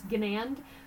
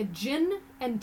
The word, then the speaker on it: Ginand: It's Ginand as my husband's family, it's their their name, always says, it's like a gin and tonic without the tonic. Gin and. Now we pronounce it with the hard G, but you can always find me on LinkedIn.